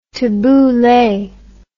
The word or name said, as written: tabuley